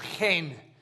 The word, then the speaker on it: chen: So in the Old Testament, we have a word, and it's pronounced chen. You have to get the Hebrew sound, but it's chen.